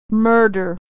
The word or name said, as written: murder